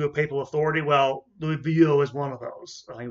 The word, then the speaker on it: Vuillot: People like, and I think I'm pronouncing this name the right way, Louis Vuillot. Vuillot, not Bio, but Vuillot,